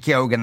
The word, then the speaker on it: Keoghan: Serena heard him on BBC Radio 1 during the week confirming it's Barry Keoghan. That's how you say his name.